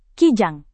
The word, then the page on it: kijang: indonesian